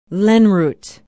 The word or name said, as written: lenroot